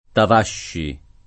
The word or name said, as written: Tavasci